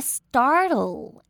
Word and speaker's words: startle: And scene, 一定要拉长 I 的音哦。Startle or, 这边的 startle, 一定要舌头往上让我听到 L 的音哦。Startle or, 一定要分开来念会比较清楚一点。Startle or distract fish and other potential predators.